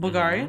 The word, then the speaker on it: Bulgari: Zendaya, our queen, she's been posting her Bulgari? Did I say that right? Bulgari. Mm-hmm.